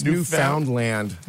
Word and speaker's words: Newfoundland: New Finland? – It's Newfoundland.